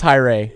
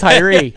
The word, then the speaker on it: Tyree: Now, his last name looks like it should be pronounced Tyree, but it's Tyree.